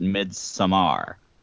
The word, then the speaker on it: Midsommar: My favorite movie from last year was far and away Midsommar, or as writer-director Ari Aster would pronounce it, Midsommar.